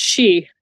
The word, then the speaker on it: sidhe: You have, I'm not going to pronounce it right, but you have S-I-D-H-E. – It's literally pronounced she, S-I-D-H-E, but it's she, but it's fairy.